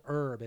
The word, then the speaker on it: Urb: Also, before we wrap this up, I'm going to recommend a product called Herb. It is spelled U-R-B.